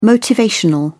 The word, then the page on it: motivational: British English